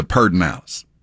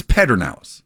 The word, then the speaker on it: Pedernales: P-E-D. I know how it's spelled, but I grew up, everybody I knew pronounced the river the Pedernales with P-E-R-D. They put an R where there shouldn't be. But when you read the name of the river, it's Pedernales. But everybody I knew always called it Pedernales.